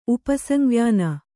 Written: ಉಪಸಂವ್ಯಾನ

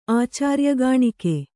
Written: ಆಚಾರ್ಯಗಾಣಿಕೆ

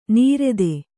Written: ನೀರೆದೆ

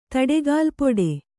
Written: ತಡೆಗಾಲ್ಪೊಡೆ

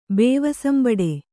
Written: ಬೇವಸಂಬಡು

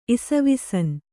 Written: ಇಸವಿಸನ್

